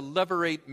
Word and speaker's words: levirate: levirate marriage. Let me spell that word for you. It's L-E-V, as in Victor, I-R-A-T-E. L-E-V-I-R-A-T-E, levirate marriage.